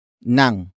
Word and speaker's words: ng: The next word is nang, which is spelled as ng.